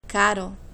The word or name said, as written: caro